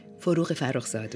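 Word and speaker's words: Forough Farrokhzad: les On va écouter la voix de Farouk Farouzad. Comment vous le prononcez Farouk Farouzad, oui.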